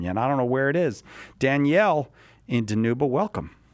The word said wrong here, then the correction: Dinuba: It's actually pronounced Dinuba.